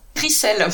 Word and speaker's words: Rijsel: Rissel. – Rissel. – Qui s'écrit R-I-J-S-E-L. – Donc avec un J.